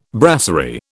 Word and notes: brasserie: British English

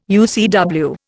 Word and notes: UCW: Indian

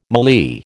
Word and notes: mallee: American English

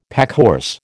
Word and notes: packhorse: American English

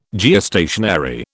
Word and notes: Geostationary: British English

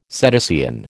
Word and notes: cetacean: American English

